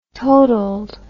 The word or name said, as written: totaled